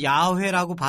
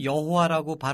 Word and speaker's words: 야훼: 그래서 우리는 야훼라고 발음하는 게 옳다. 개신교 쪽에서는 여호와라고 발음하는 게 옳다.